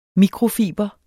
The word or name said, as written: mikrofiber